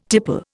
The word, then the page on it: tipple: Indian